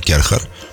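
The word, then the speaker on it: Kärcher: Bun, deci în concluzie avem acest concurs de la Kerher, care și-a pus practic toată încrederea în mâinile noastre. Încrederea în primul rând că o să reușim să pronunțăm numele Kerher.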